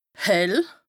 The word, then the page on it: hell: Bas-Rhin; Strasbourg